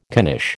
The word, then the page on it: knish: American English